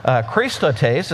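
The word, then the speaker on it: Christotes (χρηστότης): Kindness comes from a Greek word, Christotes is how you say that.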